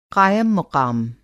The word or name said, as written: क़ायम-मुक़ाम